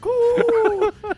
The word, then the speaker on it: Koo: Young Ho Koo. Is that how you say it? – Yeah, just like that. Exactly like that. – Young Ho Koo.